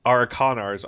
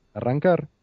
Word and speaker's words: Arrancar: And you have such a Arakanars. I can't pronounce this. Isn't this a Spanish word? – Arrancar.